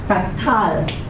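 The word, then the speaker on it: qatal: Now, the accent would be on the second syllable. Tatal.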